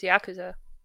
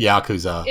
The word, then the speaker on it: Yakuza: Yes, like, Yakuza. – If it's yes. – if it's Japanese, there's like no emphasis on any syllable. – Okay. – Either way is fine in English, but I want to play the Fist of the North Star one.